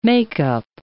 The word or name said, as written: makeup